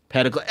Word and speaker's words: pedicle: The funny thing about pedicle is everybody pronounce it like medical to rhyme like medical. Because that's what Anthony Bubinick, a famous antler researcher, said, that it rhymes with medical.